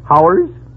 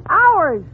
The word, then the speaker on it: hours: Now, how do you pronounce H-O-R-S-E? – H-O-R... Oh, that's easy. That's horse. – Fine. Now, how do you pronounce H-O-U-R-S? – H-O-U-R-S... Oh, Howers? – You pronounce it ours.